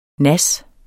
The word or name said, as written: nas